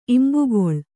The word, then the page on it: ಇಂಬುಗೊಳ್: imbugoḷ